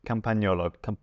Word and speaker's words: Campagnolo: And the last one on the list is Campagnolo. – Good pronunciation, like that. – Actually, no, I pronounced the G. Campagnolo.